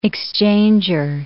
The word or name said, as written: exchanger